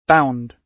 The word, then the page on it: bound: British English